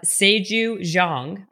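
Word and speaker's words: Saeju Jeong: Today on Habits and Hustle, we have Seju Jung.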